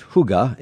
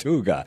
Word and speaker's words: hygge: So Garrett, you call your working space Huga. It's spelled H-Y-G-G-E. – Yes. – It's Danish. What does it mean? Why is it pronounced Huga? – Danish.